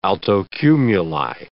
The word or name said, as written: altocumuli